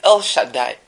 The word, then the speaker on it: El Shaddai: When we say El Shaddai, is the Hebrew pronunciation for that expression.